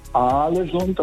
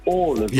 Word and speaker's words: Ålesund: Also das heißt nicht Alesund, das heißt Ålesund.